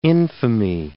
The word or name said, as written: infamy